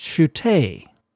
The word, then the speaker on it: Shutta: whose last name, spelled S-H-U-T-T-A, I mispronounced as Shutta, when in fact, as was pointed out by sharp-eared listener Sam in Corpus Christi, Texas, it's pronounced Shute.